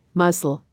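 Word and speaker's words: musl: Muscle, pronounced like the word muscle or muscle,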